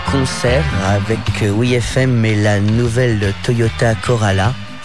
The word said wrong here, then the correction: Corolla: Corolla, pardon. J'écorche le nom du sponsor.